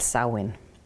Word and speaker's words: Samhain: Den staves jeg nemlig Samhain, men udtalt Samhain.